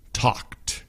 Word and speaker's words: talked: 5, 4, 3, 2, 1, talked, talked. So this one has the ED at the end, right? But it just sounds like one syllable. T-A-L-K-E-D.